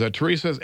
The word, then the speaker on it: Turisas: It was, let's see, it was Turisas, Ensiferum headline, Turisas, Tyr, and Ellie Vaete were on that tour.